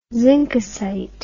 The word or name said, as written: zinkosite